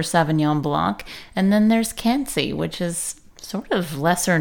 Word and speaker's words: Quincy: Not at all very far away, in fact, and they probably would also pronounce it Quincy, but it's in France where it's Cancy.